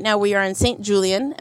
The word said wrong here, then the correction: St. Julian's: Am I saying it right? – St. Julian's. – Yeah, at St. Julian's.